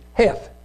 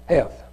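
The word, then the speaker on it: Cheth: The next one is, Heth. Even though there's a C there, I think it's pronounced Heth.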